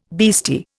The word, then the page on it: beastie: Indian